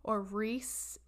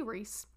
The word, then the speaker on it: Rhys: Well, this is what Reese, it's spelled R-H-Y-S, so it could be Reese, Owens, and Carrie Mules woke up to one morning in Australia in 2012.